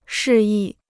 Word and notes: 示意: shì yì

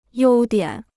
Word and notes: 优点: yōu diǎn

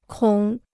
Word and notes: 孔: kǒng

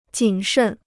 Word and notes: 谨慎: jǐn shèn

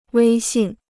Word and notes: 威信: wēi xìn

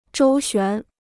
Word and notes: 周旋: zhōu xuán

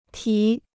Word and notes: 题: tí

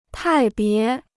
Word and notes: 派别: pài bié

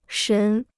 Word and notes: 神: shén

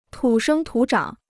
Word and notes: 土生土长: tǔ shēng tǔ zhǎng

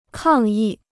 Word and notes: 抗议: kàng yì

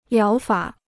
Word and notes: 疗法: liáo fǎ

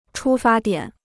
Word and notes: 出发点: chū fā diǎn

